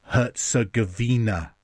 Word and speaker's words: Herzegovina: And thank you for giving me guidance on the pronunciation of Herzegovina.